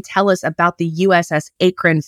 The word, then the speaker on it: Akron: So obviously built at, we pronounce it Akron.